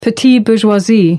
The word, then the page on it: petite bourgeoisie: American English